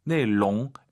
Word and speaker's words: 内容: 자, 발음하실 때 내롱, L 발음 안 돼요. 내롱, 롱 말아서 내롱.